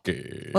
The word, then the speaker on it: Cocchi: You're not saying it right. You're drinking there beautiful. – Cocchi. – Well, there you go. You do it quite well.